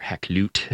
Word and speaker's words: Hakluyt: And it informs a lot of at the very end of the article, I talk a little bit about Richard Hakluyt, mm-hmm. or Hakluyt, as his name is probably supposed to be pronounced, who draws on Drake's experience to inform a lot of the rhetoric around colonization.